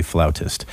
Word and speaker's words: flautist: She's like an up-and-coming flautist, by the way, in case you're wondering how you pronounce someone who plays the flute. It is a flautist.